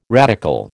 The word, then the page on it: radical: American English